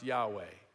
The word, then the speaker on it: Yahweh: Up here, we couldn't do small caps, so it's just capital L-O-R-D. And that's translating something altogether different. That's translating these four Hebrew letters, Y-H-W-H, which most biblical scholars say should be pronounced Yahweh.